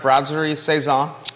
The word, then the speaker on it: Brasserie Saison: So you're having cocktails and dinner at Brasserie Saison. Did I say that right, Judah? Thank you. – Pretty much.